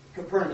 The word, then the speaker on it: Capernaum: Capernaum, is that how you say it? – Yeah.